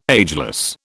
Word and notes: ageless: British English

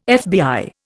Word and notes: FBI: Indian

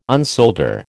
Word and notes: unsolder: American English

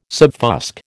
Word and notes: subfusc: American English

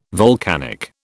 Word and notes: volcanic: British English

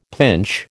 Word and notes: pinch: American English

